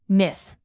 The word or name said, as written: myth